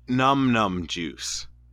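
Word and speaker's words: Numb Numb Juice: I think it's called Num Num Juice. But yep, that's what it's called. Dude. – Is it? Num Num. Yeah. It's spelled N-U-M-B-N-U-M-B juice. – Yeah, like your arm is numb.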